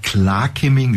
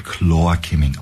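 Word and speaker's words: Klar Kimming: Und das Klar-Kimming, wie es geschrieben wird, wird Chlor-Kimming ausgesprochen.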